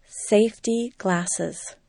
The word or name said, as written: safety glasses